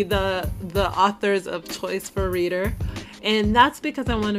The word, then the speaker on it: ReadHer: It's called Reader. It's spelled R-E-A-D-H-E-R. And it's because it's a subscription book box specifically created for women.